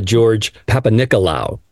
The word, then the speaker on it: George Papanicolaou: So let's talk a little bit about this immigrant from Greece, George Papanicolaou.